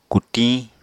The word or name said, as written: kutiñ